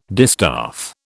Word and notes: distaff: British English

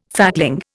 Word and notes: fatling: Indian

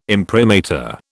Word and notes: imprimatur: British English

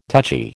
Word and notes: touchy: American English

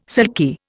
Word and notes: silky: Indian

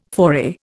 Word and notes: foray: Indian

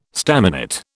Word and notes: staminate: British English

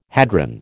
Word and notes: hadron: American English

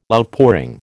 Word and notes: Outpouring: American English

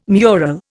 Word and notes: mural: Indian

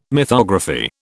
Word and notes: mythography: British English

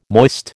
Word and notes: moist: American English

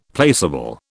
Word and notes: placable: British English

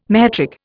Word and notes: metric: Indian

